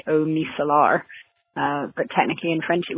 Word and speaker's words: eau micellaire: It's eau micellaire. So the O sound in French is kind of like the O in a word like cold. It's eau, eau.